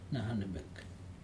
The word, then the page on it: nahanemek: Aneityum